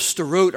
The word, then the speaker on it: Sderot: Sderot. S-D-E-R-O-T, pronounced Sderot.